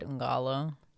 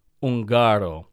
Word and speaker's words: Ungaro: I think I might be pronouncing it wrong because it's Ungaro. So you emphasize the ga. – Okay, so I'm on the right track by saying Ungalo.